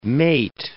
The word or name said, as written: mate